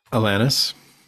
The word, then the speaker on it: Alanis: Alanis.